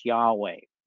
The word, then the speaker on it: Yahweh: they substitute L-O-R-D in caps. According to scholars, we suggest the original pronunciation was Yahweh.